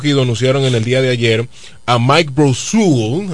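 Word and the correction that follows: Brosseau: así que se pronuncia, ¿verdad? – Eh, utility. – Sí. – Brosó. Brosó. Mike Brosó en Utility.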